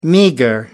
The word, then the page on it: meagre: American English